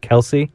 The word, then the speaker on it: Kelce: But did you know it's not actually pronounced Kelce? It's like your freaking last name. Kelce. No one knows how to say it right.